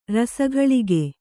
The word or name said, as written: ರಸಘಳಿಗೆ